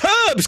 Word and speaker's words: herbs: I never get why the Americans say herbs. There's an H there. Say the H. It's herbs, goddammit.